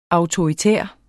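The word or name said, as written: AUTORITÆR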